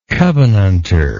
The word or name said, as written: covenantor